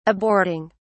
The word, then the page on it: aborting: British English